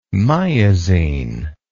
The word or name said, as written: miazine